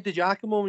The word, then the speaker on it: Digiacomo: Saying Digiacomo when you're ordering a Chinese is difficult.